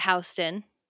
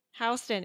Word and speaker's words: Houston: Then we've also got Houston. – Houston. It's spelled like Houston, but it's not Houston.